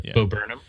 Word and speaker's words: Bo Burnham: Like the whole Bo Burnham, I'm saying that right? Right? – Yeah. Bo Burnham.